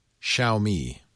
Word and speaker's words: Xiaomi: And also OnePlus, Nokia, and is it pronounced Xiaomi? – Xiaomi.